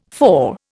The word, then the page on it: for: Indian